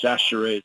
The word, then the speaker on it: Sachere: If you look at his name, it looks like Chichiri, but it's pronounced Sachere.